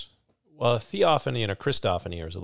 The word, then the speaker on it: Theophany: Theophany? – Yes.